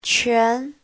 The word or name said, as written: quan2